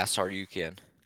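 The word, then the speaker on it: Tsarukyan: I think I have to go with the Oliveira Sarukian. Is that how you pronounce his last name? – Yeah, Sarukian.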